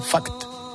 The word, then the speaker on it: fact: Pero segundo, he tenido que enseñarle hasta pronunciar fact. – Fact. – Sí, porque ha dicho fact. Y eso fact. Sí, es importante la T.